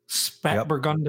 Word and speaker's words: Spätburgunder: That's how you pronounce it? – Spätburgunder. Yep.